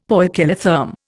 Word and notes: poikilotherm: Indian

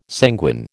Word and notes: sanguine: American English

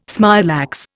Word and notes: smilax: Indian

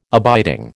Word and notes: abiding: American English

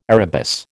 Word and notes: arabis: American English

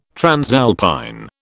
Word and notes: transalpine: British English

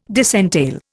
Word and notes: disentail: Indian

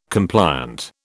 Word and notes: compliant: British English

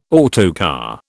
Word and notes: autocar: British English

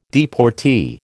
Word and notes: deportee: American English

